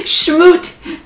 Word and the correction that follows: Smoot: No, Smoot Schmid.